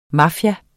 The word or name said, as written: mafia